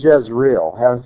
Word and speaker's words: Jezreel: The word Jezreel has to do with sowing seed. It sounds, when it's pronounced, it sounds almost exactly like Yisrael. Yisrael and Yisrael, Jezreel and Israel, sound about identical.